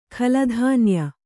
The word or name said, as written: ಖಲಧಾನ್ಯ